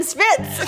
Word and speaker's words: Schwitz: Where we talk shit and schwitz. – Schwitz. Gabby doesn't know how to say it yet. It's okay. Schwitz. We schwitz it out.